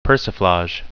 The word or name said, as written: persiflage